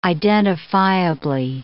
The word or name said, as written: identifiably